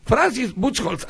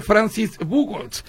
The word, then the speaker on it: Francis Buchholz: Francis Buchholz, así me dijeron que en alemán se pronuncia, aunque en inglés podría ser Francis Buchholz.